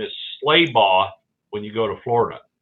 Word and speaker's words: Schlabach: Schlabach.